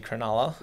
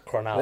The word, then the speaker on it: Cronulla: It's actually Cronulla. – Yeah, I see Cronulla. – How do I say it? – Cronulla. What are you saying? – Cornelia. – Just say it. – Cronulla. – Cronulla. – There we go. You got it now, yeah.